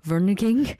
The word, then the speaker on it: vereniging: This essentially, there's a Dutch tradition of, I really tried so hard to listen to this word and pronounce it correctly. It's vereniging.